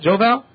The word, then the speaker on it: Joe Val: Am I correct to pronounce this like that, Joe Val? – That's exactly correct.